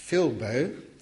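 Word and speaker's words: philbo (θλίβω): The Greek word for narrow is philbo, that's the correct pronunciation, and this word is actually unique to our parable.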